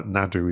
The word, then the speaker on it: Naru: And going back to the very first one with the character of Naru, I'm told that Nadu is the way you pronounce it in Comanche.